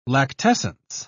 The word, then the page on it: lactescence: British English